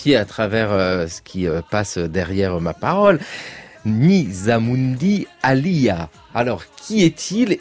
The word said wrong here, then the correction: Nizamuddin Aulia: Et déjà, corrigez-moi la prononciation, Corinne Lefebvre. – Nizamuddin Aulia, c'est un saint soufi du XIVe siècle.